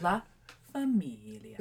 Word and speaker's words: La Famiglia: As I said before, I looked it up on Google how to actually say it, because I think it's La Famiglia. – It is not La Famiglia. – I was corrected, but we discerned that it's La Famiglia. Yeah. And you have to say it like that, too.